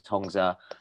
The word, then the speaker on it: Hongza: My name is Hongza.